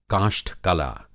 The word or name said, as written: काष्ठ-कला